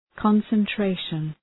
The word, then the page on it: concentration: {,kɒnsən’treıʃən}